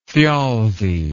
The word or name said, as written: thjalfi